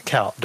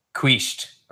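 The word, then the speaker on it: Kuyt: I said Coit. I'm sorry. Kout. Dirk Kout. My bad.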